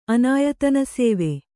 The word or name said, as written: ಅನಾಯತನಸೇವೆ